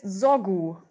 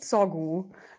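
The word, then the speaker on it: Zogu: Der gute Herr heißt Sorgu und nicht Sorgu. Also das ähm, albanische Z spricht man wie das deutsche S aus.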